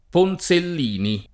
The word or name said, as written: Ponzellini